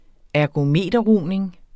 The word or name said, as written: ergometerroning